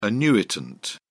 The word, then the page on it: annuitant: British English